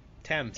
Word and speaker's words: Thames: Thames is how it's pronounced, apparently.